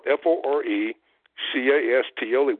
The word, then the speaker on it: forecastle: It's spelled F O R E C A S T L E, which means the front of the ship. It's a forecastle.